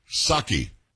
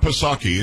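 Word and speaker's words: Psaki: Saki. – Saki? – Saki. It's not Pasaki. It was just Saki. You didn't pronounce the P.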